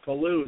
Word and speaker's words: Pellew: Pellew, I'll tell you what, Pellew is not a whole lot better. I get Palau and Pillow and what did my parents do into us, right? – Yeah.